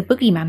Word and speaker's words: boogeyman: The boogeyman, I said it right. What did I say to it? What did I call it before? – The bogeyman. – No, the bogeyman is what we Brits call it.